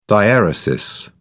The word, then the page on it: diaeresis: British English